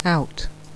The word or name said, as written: out